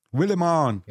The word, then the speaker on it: Willimon: And directed by Toby Haynes, and written by Bo Willimon. – Willimon. – Yo, I said that correctly, actually.